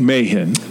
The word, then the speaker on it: Mahon: Mike Mahon. M-A-H-O-N, Mahon.